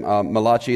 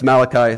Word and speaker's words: Malachi: there's a guy named Malachi, the Italian prophet. I always like saying that. No, it's Malachi. That's how you say it.